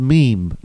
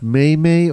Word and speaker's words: meme: It's pronounced meme, not may-may or meme.